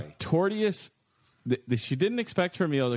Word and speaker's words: tortious: When a woman ordered lasagna at a restaurant in the suburbs, she didn't expect her meal to come with a side of tortuous conduct. Am I saying it wrong? Torturous? There's no other R. T-O-R-T-I-O-U-S.